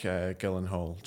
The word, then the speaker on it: Gyllenhaal: So Jake Gyllenhaal, yeah.